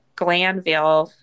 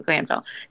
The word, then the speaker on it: Glanville: anyway, in the entry for May of 2022, Brandi Glant Glanville, did I say his name? Glanville.